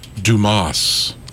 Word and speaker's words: Dumas: And he walks in and he's snickering at the guy's name, and the guy says, "It's pronounced Dumas."